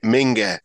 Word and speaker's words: Minge: You wouldn't pronounce it this way in German. You'd pronounce it Minge. But when you look at it in English, the German footballer's name was Ralf Minge.